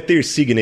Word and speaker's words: Tersigna: Pronuncie o seu sobrenome corretamente? – Sim, sim. Ah, é difícil, mas é assim mesmo. – Bom, o meu sobrenome é Tersigna, italiano, então você imagina também que o pessoal faz uma confusão danada.